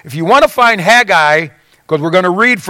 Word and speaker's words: Haggai: His name is Haggai. That's how it's pronounced. Haggai. It's not Haggy Guy. It's Haggai.